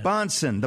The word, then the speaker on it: Bahnsen: Like Johnson with a B, but spelled differently. – So it is correct. Yeah. Bahnsen,